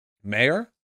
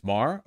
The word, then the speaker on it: Maher: Mayor? Is it Mayor? Why would you not type it phonetically? Maher? Maher? It's Maher? Okay, thank you.